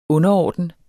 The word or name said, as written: underorden